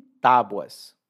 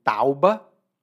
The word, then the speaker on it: tábua: Ela também pegou martelo, prego e tábuas. A tábua, que às vezes as pessoas pronunciam de maneira errada e dizem tauba, quando eu era criança, eu falava também assim, mas a tábua é um pedaço de madeira, mas é um pedaço plano, geralmente comprido também.